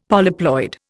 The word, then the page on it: polyploid: Indian